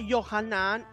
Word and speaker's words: Yohanan: Juan en hebreo se pronuncia Yohanan y significa Hashem fue misericordioso o Hashem mostró favor.